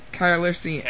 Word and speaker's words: Calrissian: Sorry, how do you pronounce it? – Carlisson. – Calrissian. – Calrissian. – Calrissian. – Calrissian. – Calrissian. Yes.